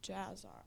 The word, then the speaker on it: Jasher: Hand of Seed said, off topic question, what is your opinion on the legitimacy of the book of Jasher? – Just spell it. It's Jasher.